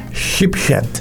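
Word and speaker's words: Sheepshead: Sheephead.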